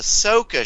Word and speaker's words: Ahsoka: Oh, they cast Ezra for the Ahsoka show. God, I had to get up chat to see what they're talking about. – You pronounced it wrong.